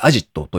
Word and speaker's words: AZIT: ラストワンマイル配送プラットフォームクルーエクスプレスを運営する株式会社アジット、 AZIT と書いてアジットというもそうなんですけれども、こちらの会社が資金調達をしたよという発表ですね。